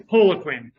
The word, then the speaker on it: Poliquin: It's Poliquin.